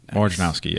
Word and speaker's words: Wojnarowski: I mean, you see, like Adrian Wojnarowski or whatever. That's how you say his name, mm-hmm, right? Okay. Wojnarowski. – Wojnarowski, yeah.